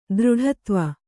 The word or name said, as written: ದೃಢತ್ವ